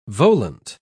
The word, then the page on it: volant: British English